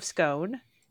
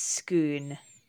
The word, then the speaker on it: Scone: The American pronunciation is just straight up Stone of Scone. – Well, that, yeah, but American pronunciations are usually wrong. – Yeah. And then the British pronunciation is more Stone of Scone, according to Wikipedia. – Okay, good. Good. – Oh, but Scottish Gaelic, it's Scone. – Okay, well, yeah. Then that's what we'll go with.